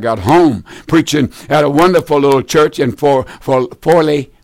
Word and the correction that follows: Foley: Foley, I think I pronounced that right, Foley, Alabama. That's halfway, somewhere like halfway between Pensacola and Mobile.